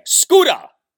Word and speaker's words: Scoota: I defy you to name me a better nickname than Scuda, which is spelled S-C-O-O-T-A. Scuda.